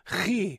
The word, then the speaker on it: chi: The chi, pronounced with a ch and a he, chi.